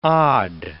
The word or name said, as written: odd